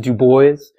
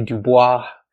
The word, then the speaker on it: Du Bois: The correct pronunciation is Du Bois. A lot of people say Du Bois, but he would always correct them on this.